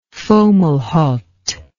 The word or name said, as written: Fomalhaut